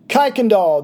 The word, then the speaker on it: Kuykendall: Kuykendall. There we go. So Jerry Kuykendall, and I'm going to just turn a little bit of this over to you guys.